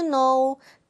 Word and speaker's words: know: 자, 발음은 no이지만, 뭘로 시작해요? No. 저 K로 시작합니다. Do you know? Know 아니에요? No. 발음은 no입니다.